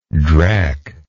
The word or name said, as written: drecky